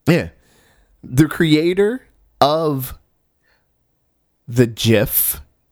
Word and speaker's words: GIF: It is a soft G pronounced GIF.